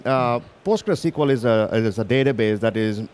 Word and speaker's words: PostgreSQL: Let's talk about PostgreSQL. Did I say it right? PostgreSQL, yes.